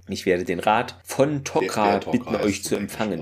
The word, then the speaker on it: Tok'ra: Interessanterweise wird es hier auch richtig ausgesprochen, nicht wie in der vorherigen Folgen Tok'ra. Jetzt ist es hier Tok'ra wieder. Hoffen wir, es bleibt dabei.